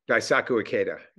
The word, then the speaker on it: Daisaku Ikeda: Daisaku Ikeda, yeah.